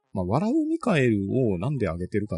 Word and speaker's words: 大天使: 大天使って書いてミカエルなんですね。 – そうですね。漢字で笑う大天使と書いて笑うミカエルと読むやつですね。